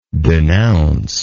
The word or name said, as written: denouncer